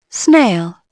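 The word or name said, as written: snail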